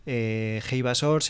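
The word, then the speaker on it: Heyva Sor: Eibasor se escribe Heyva, Sor, tal como suena, Kurdistane.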